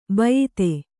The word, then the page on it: ಬಿಯದಿ: biyadi